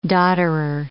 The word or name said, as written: dodderer